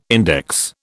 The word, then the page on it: index: British English